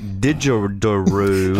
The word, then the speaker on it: didgeridoo: But yet he's able to take didgeridoo. – Didgeridoo. – And he says it right the first time without any effort.